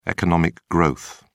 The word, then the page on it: economic growth: British English